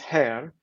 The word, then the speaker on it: Xeer: The second one is Hir. It's spelled, in English it would be spelled, it looks like it's spelled X-E-E-R, which is, as you said, a Somali term for custom or customary law.